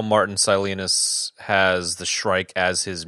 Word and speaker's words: Silenus: And one of the things I thought was quite fascinating was how Martin Salinas or whatever the fuck it is. Salinas? – Oh my God. You know how they say it in the audiobook? – How? – Silenus.